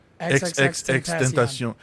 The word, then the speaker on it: XXXTentacion: XXXTentacion.